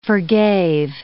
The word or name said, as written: forgave